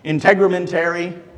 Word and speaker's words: Integumentary: Integumentary, is that correct? Alicia said, close enough.